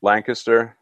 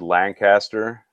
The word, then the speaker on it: Lancaster: So we're based out of Lancaster, and make sure you say it right. It's spelled Lancaster, but if you say it like that, you might get a weird look. It's pronounced Lancaster.